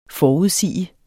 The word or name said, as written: forudsige